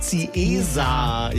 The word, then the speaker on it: Ziesar: Heißt natürlich Teschendorf und Ziesar. Ja, aber das sind so Klassiker, die immer wieder falsch ausgesprochen werden bei uns.